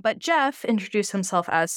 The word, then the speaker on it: Gef: Shortly after this took place, the entity behind the walls introduced itself properly to the family as Jeff, which it's spelled G-E-F.